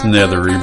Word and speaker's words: Nethery: Well, I hate to say it, but Tommy said my last name wrong. It's Nethery, but hey, look. Everybody puts the L in there since I was a little kid.